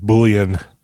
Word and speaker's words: Bouillon: You kind of butchered that one. It's Beef Bouillon. – Oh, sorry. – You get it? Beef Bouillon? – I get it. – No, it's Bouillon. – It is Bouillon.